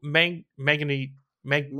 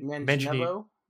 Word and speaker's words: Manganiello: Joe Mang Mangani Mang Manganiello.